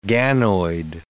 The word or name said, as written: ganoid